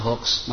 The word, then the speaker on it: hoax: Tulisannya hoax tapi bacanya hoax, mas.